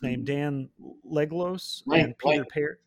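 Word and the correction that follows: Langlois: Dan Langloy. – Langloy, okay.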